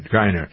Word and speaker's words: Griner: Is it Griner or Griner? I never pronounce her name right. Griner.